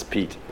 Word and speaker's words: Piet: Yeah, and or as some people read it, because it's spelled P-I-E-T, they say Piet, but it's Pete.